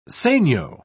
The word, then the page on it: segno: British English